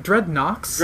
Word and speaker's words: Dreadnoks: Is it Dreadnoks? – Dreadnoks. – Yeah. – How do you spell that? – N-O-K-S. – Yep.